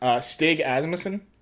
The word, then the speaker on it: Stig Asmussen: We know Stig Asmussen. I think that's how you say his name. Very difficult name. – I believe so, yeah.